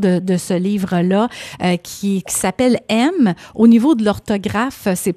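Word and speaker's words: Em: E-M. Mais ça se lit vraiment comme « aime », comme le verbe « aimer » à l'impératif.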